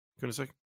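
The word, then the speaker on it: Kunaszyk: I thought it was interesting that, you know, De'Anthony Bell and Jordan Kunisic, right? That's how you say it, isn't it? Kunisic? – Sounds good to me.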